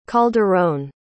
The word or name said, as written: calderon